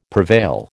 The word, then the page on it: prevail: American English